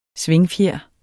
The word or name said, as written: svingfjer